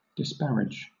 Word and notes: disparage: British English

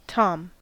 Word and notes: tom: American English

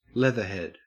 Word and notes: leathered: Australian English

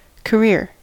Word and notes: career: American English

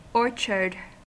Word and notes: orchard: American English

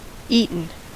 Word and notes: eaten: American English

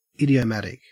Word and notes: idiomatic: Australian English

